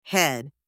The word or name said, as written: head